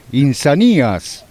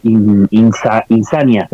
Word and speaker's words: Insanías: Con trabajo reciente, Insanías o Insania. – Sí, es un debate ese sobre cómo se pronuncia. Debería ser Insania, según la Real Academia Española. Pero bueno, a nosotros nos suena mejor Insanías.